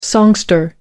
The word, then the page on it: songster: American English